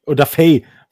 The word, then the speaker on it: Odafe: Meine zweite Bold, Bold Prediction ist ähm, Jason Away 8-6. – Der heißt Odafe. – Ach, Odafe. Odafe. Odafe von der Betonung her.